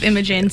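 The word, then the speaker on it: Imogen: I love Imogene, so... – Okay. You even say it properly.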